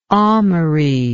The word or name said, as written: almery